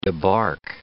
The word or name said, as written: debark